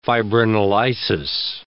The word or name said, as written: fibrinolysis